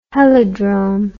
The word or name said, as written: helidrome